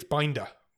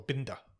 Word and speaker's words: Binder: And so directed by Felix Binder, or Binder, I guess is how you'd pronounce it in German.